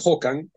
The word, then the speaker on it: Håkan: Håkan, exactamente es Håkan, porque es sueco, ahora lo diremos, la pronunciación es rara.